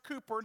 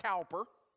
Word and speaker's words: Cowper: That is pronounced Cooper, not Cowper. Cooper.